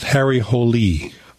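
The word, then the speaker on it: Harry Hole: My wife, who read the Snowman book or books, said it's pronounced Harry Holy.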